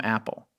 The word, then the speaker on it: Apol: A-P-O-L, pronounced Apple.